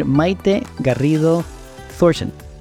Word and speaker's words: Maite Garrido Thornton: Today we have Isabel Custer and Maite Garrido Thornton.